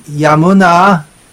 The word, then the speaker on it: यामुना: यमुना इस प्रसंग में यामुना ऐसे उच्चारण होना चाहिए.